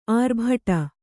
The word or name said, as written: ಆರ್ಭಟ